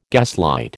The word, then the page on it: gaslight: American English